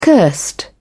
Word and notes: cursed: British English

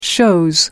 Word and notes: chose: American English

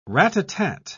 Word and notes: rat-a-tat: British English

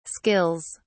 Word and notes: skills: British English

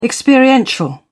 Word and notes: experiential: British English

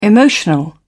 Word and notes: emotional: British English

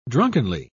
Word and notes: drunkenly: British English

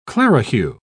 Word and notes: clerihew: British English

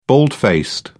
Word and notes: bald-faced: British English

